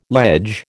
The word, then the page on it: ledge: American English